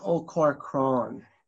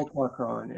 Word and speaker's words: Ó Corcoráin: Sean O'Corcoran. – Okay. – Yeah, perfect. O'Corcoran, yeah. It's the Irish version of my name. The English version is Sean Corcoran, but I don't use that.